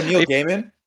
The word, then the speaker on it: Neil Gaiman: Neil Gaiman. How do you pronounce it? – Oh, Neil Gaiman? – Yeah, it's okay.